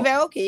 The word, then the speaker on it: Aoki: Yeah, Steve Aoki, yeah.